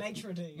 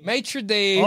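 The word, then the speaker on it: maître d': Maitre d'. – Maitre d'.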